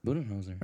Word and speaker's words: Budenholzer: So, we have Mike Budenholzer, who wins... right? From the Bucks. Did I butcher it? – Yeah. – Yeah, all right, cool. How do you pronounce it? – Budenholzer.